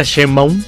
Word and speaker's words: Sheinbaum: ¿Lo dije bien? Claudia Sheinbaum. – Sí, sí lo dijiste bien.